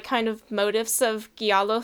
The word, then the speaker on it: giallo: It's pronounced giallo.